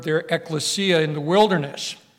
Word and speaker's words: ekklesia: Ekklesia is spelled E-K-K-L-E-S-I-A. E-K-K-L-E-S-I-A, ekklesia.